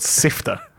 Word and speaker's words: SYFTA: And they are a band called Sifter. So that's spelled S-Y-F-T-A, all in capital letters.